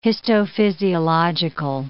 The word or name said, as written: histophysiological